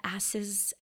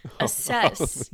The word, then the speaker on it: assess: One funny bit, one of the girls goes, you have to asses the window? Because assess. Oh, yeah. You put that emphasis on the wrong syllable.